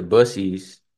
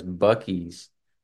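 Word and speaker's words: Buc-ee's: You and I went to Bussy's, spelled Bravo Uniform Charlie Echo Echo Sierra, some pronounced by some as Bucky's.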